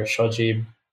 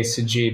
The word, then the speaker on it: Sajeeb: Like, like my real name is Shoji, but I say Sajib because it's easier to say and it's spelled with an S and not an SH.